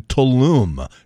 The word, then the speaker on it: Tulum: You know, I still remember it was in Tulum. It's spelled T-U-L-U-M. It's pronounced Tulum.